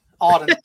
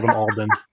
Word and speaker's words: Auden: Auden. – Gotta say the name right. I think you're out because you just called him Alden.